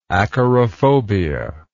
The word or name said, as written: acarophobia